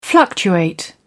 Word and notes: fluctuating: British English

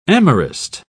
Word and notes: amorist: British English

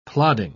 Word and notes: plodding: British English

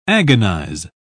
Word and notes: agonise: British English

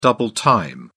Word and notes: double time: British English

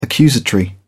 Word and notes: accusatory: British English

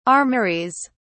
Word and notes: armouries: British English

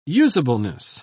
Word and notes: usableness: British English

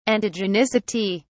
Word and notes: antigenicity: British English

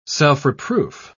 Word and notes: self-reproof: British English

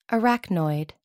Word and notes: arachnoid: American English

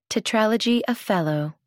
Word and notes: tetralogy of fallot: American English

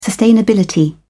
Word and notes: sustainability: British English

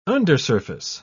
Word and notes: undersurface: British English

